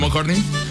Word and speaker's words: McCartney: ¿McCartney? – Sí, señor. – Paul McCartney.